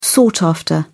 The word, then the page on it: sought after: British English